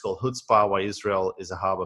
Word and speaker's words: chutzpah: By the way, if you're wondering what chutzpah means, it's a noun and it's pronounced chutzpah.